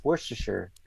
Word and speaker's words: Worcestershire: It's like it's not Worcester. I got corrected many times. It's Worcestershire.